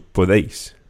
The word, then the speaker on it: podéis: And just one thing when you're pronouncing that vosotros form, the podéis, the E and the I of podéis kind of run together. There's not really three syllables in there, podéis, but you run the D, the deis together, so podéis.